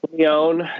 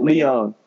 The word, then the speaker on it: Lyon: You said Leon. Do you know how to say it? – Leon is how I say it. – Leon. I think that's right.